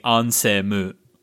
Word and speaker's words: Ansem: And even in the English version, the lip shapes still say Ansemu. So there's three syllables, not two.